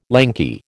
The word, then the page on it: lanky: American English